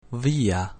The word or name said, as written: via